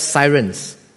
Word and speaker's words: Seirenes: It's spelled as S-E-I-R-E-N-E-S, right, Sirens.